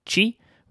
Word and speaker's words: Qi: That Apple have adopted this standard called Qi, which is spelled QI, which I would love to understand why we're doing that.